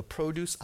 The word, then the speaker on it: produce: The produce aisle, P-R-O-D-U-C-E. Interesting. Even though we pronounce it differently in its noun form, it's still the very same word that so often we say in the verb form, produce, produce.